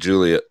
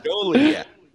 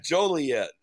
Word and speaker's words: Joliet: It is Joliet. – Joliet? – Joliet.